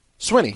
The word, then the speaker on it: Swinney: Do you say Sweeney? – Sweeney. – I pronounce it properly the way it's spelled. – How do you pronounce it? – Swinney. – Swinney. – I don't go full Sweeney. I thought it's Swinney. – Is that true, Michael? – I think it's Dabo Swinney.